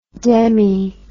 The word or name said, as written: demi